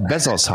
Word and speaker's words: Bezos: Ich, ich habe gerade nämlich überlegt, ich habe letztes Mal habe ich Bezos gesagt, da bist du so auf die Palme gegangen. Erinnerst du dich noch ein bisschen? Ja, weil der auch Bessos heißt. Also so wird es ausgesprochen.